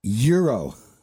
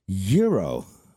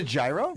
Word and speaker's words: gyro: A good G-Y-R-O. How do you say that? – Euro. – Do you really? – Yes. – Wait, okay. Say that again? – Euro. – So is it? – How do you say it? – Okay. So if that's a euro. – Is it a gyro? – No, it is not a gyro.